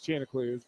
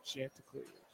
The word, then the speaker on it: Chanticleers: The Cardiac Cards versus the Chanticleers, and it is Chanticleers, not Chanticleers.